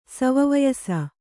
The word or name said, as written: ಸವವಯಸ